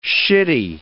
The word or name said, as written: shitty